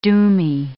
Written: doomy

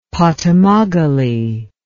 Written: potamogale

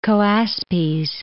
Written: Choaspes

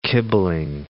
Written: kibbling

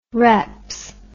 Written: reps

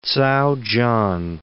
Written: Ts'ao Chan

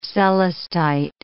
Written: celestite

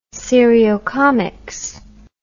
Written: seriocomics

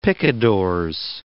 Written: picadors

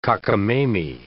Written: cockamamie

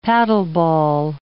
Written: paddleball

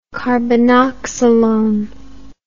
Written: carbenoxolone

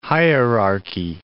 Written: hierarchy

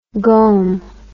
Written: gome

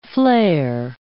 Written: flair

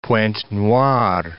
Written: Pointe-Noire

